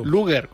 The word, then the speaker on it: Luger: Luger o Lager, ¿cómo se dice esto? – Luger. Como Luger. – Es la marca de la pistola.